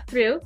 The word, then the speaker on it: through: And there was a song that went like, O-U-G-H, O-U-G-H, O-U-G-H, I'm through, to teach you how to pronounce the word through with those O-U-G-H. – Okay. – So, yeah, through has O-U-G-H, it's the U sound. But there are other words like dough, which has O-U-G-H, and it's not U, it's O, dough.